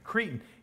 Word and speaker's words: Cretan: He quotes from a Cretan, by the way, Cretan, it sounds like that word that you say to people, you know, if you're trying to put them down. That's actually a different word. That's C-R-E-T-I-N. It's pronounced the same way. This is C-R-E-T-A-N, but it has the same pronunciation.